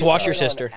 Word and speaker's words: Worcestershire: It's Worcestershire sauce. – Like it's Worcestershire. – Worcestershire. – It's Worcestershire. – Worcestershire. – It's Washer Sister.